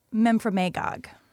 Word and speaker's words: Memphremagog: You're from Memphremagog, right? – So close. – Mm-hmm. – Lake Memphremagog. – Lake Memphremagog. Okay, that Memphremagog. Sounds Memphremagog.